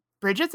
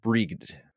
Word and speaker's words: Brigid: But Brigid's a badass bitch, man. – But this one, I actually looked through multiple videos, and the actual Irish seeming people who were saying this word pronounce it Brigid.